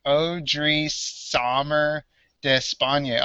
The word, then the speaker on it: Odrisamer Despagne: All right, so Baseball Reference actually does have a pronunciation guide. So it's Odrisamer Despagne. Oh, it was close.